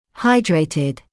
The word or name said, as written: hydrated